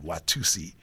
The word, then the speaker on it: Watusa: And then we went to Watusi, although it's spelled W-A-T-U-S-A. So we always called it Watusi.